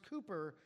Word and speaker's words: Cowper: It's spelled Cowper, C-O-W-P-E-R. It's pronounced Cooper.